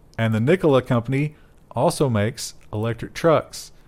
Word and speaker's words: Nikola: Whose first name was Nikola. Is it Nikola or Nikolai? Never can figure out how to properly pronounce. I think it's Nikola in his case.